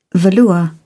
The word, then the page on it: velour: British English